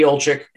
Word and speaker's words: Olczyk: You mentioned Eddie Olczyk, and I think I pronounced his name correctly.